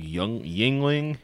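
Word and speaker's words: Yuengling: And then one called Yingling, but it's spelled like Youngling, like it's weird, like it's yes.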